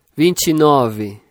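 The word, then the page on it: 29: Brazilian Portuguese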